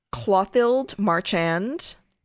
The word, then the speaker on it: Clothilde Marchand: Then in 1930, Clothilde Marchand, I think is how you pronounce her name. I know. C-L-O-T-H-I-L-D-E. Marchand.